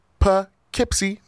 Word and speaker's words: Poughkeepsie: And speaking of real quick, Gainesville, Florida, Fort Myers, Florida, Jacksonville, Florida, Shippensburg, Pennsylvania, Atlantic City, New Jersey, Patchogue, New York, Poughkeepsie, New York. Did I say it wrong? I did it right? Yes!